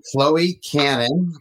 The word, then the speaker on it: Chloe Cannon: So I'd like to introduce you to Chloe Cannon.